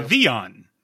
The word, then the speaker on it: Vivillon: Vivion. Vivion. – What? – Vivion. – Wow. – So V-I-V-E-E-A-A-N, if you're doing phonetically pronouncing. Vivion. – Hmm. – There's no L sound.